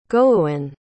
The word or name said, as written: goewin